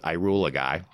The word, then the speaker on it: Ihuligi: It's Ihuligi. Which is, as Anne likes to pronounce it, I rule a guy. That's kind of how it's spelled. It's I rule a guy, but it's a.